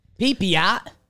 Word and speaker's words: Pepiot: And then I have read this Pepio. Okay, Ryan Pepio. I've always read it, but I'm not positive. We have a pronunciation guide here. – Pepiot. – Pepiot. Ryan Pepiot.